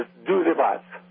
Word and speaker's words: pazzo: Means you're crazy, and "pazzo" is spelled P-A-Z-Z-O, like pizza, pazzo.